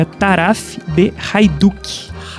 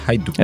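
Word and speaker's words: Taraf de Haïdouks: Eu fui ao Forvo, lá tem uma pronúncia em romeno, e é Taraf de Hajdúk. – Hajdúk. – Sem pronunciar o S ao final. – Taraf de Hajdúk. – Essa trema no I provavelmente faz com que você fale as vogais todas separadas, né? Você fala A-I mesmo, e aí é Hajdúk.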